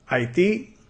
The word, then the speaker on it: Haiti: Haiti. Haiti. Haiti. Casi suena como odio. Hate. Haiti. Haiti. Y la T lo pronunciamos como una D.